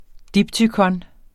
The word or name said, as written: diptykon